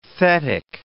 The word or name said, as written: thetic